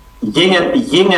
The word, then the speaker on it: Yang: Zing and Flow? – Ying and Flow. – How do you spell that? – Ying, that's Y-A-N-G, and Flow.